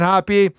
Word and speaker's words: Hoppe: Here's how I know if you're a lover of Ryan Hoppy or if you're a hater of Ryan Hoppy, because I can see this on my website. My last name is spelled H-O-P-P-E.